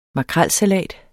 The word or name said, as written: makrelsalat